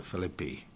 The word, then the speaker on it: Philippi: Frequently, entire families were converted, such as the conversion of the family of Lydia and the family of the prison guard at Philippi, or sometimes you hear it pronounced Philippi, and the families of Crispus and Stephana in Corinth that we'll perhaps come across later on.